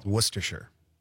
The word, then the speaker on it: Worcestershire: How do you pronounce Worcester? – Oh, I know this because I worked in Worcestershire, England. – Worcestershire, there you go. – Worcestershire. – Worcestershire. So that's how you say the sauce.